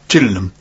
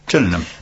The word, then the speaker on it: Gillingham: Is there a reason that you pronounce it like that? – That's Gillingham. – What they would say on trains, don't they? – Is that what they say on the trains? – They say Gillingham. – Gillingham. – That my son, for years, thought that ham handbag was spelt H-A-M-B-A-G.